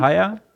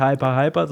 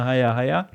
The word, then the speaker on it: Haier: Wird das Haier ausgesprochen? Haier. Nicht Hyper, sondern Haier. – Ich glaube ja. – Und also so wie ich halt schlafen gehen, ne? So ab in die Haier.